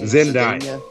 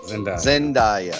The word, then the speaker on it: Zendaya: What's her name? I can't never say her name correctly. – Zandale? No. – Yeah, Zandale. – What is her fucking Zendaya. Zendaya. Zendaya.